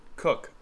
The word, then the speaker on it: Koch: Oh, fuck, how do you say his name? Jim Koch? Jim Koch? – Cook. – Jim Cook? Is it Cook? – I think so.